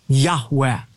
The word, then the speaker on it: Yahweh: In Hebrew, it would be composed of the four Hebrew letters, Yud He, Vav He, which most Semitic scholars believe is pronounced a breathy Yahweh.